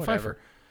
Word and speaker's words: Pfeiffer: Pfeiffer? That's what I said. – P is silent. – Pfeiffer? – Michelle Pfeiffer.